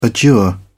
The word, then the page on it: adjure: British English